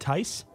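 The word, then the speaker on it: Theis: Is that how you say it? That's how you say it? Tice? – Yes.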